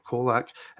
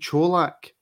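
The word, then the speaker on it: Čolak: Is it pronounced Cholak or Kolak? It is indeed Cholak, for a simple reason that on Twitter yesterday I seen him saying his own name. So that's how I know that he pronounces it Cholak.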